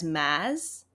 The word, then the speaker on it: Maas: I actually came across the pronunciation as Maz rather than Maz. So I've been saying it wrong.